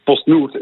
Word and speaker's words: PostNord: En nu, 1 januari 2026, heeft PostNord zelf de handdoek in de ring gegooid.